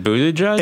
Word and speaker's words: Buttigieg: I was working on the Buttigieg campaign. I don't think I've ever said his name. I always say Mayor Pete so that I don't have to say It rhymes with booty.